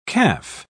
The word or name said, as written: caff